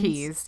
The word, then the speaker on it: Quay: Key sunglasses. Yeah. It's an Australian sunglass brand, and it's spelled Quay, but it's key.